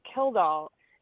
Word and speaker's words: Kildall: Because whenever anybody asks me how to pronounce my last name, I always say Kildall, as in I killed all the people who said it wrong.